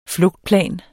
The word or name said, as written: flugtplan